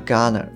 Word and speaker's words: going to: で、はい、あとはまあよく聞く音なんですけれども、えー、 going to のところは gonna のようにまあ発音されます。